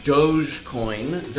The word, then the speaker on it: Dogecoin: Cryptocurrency Dogcoin is actually pronounced with a soft J, as in Dogecoin, though it does have a dog-shaped mascot.